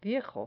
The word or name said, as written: viejo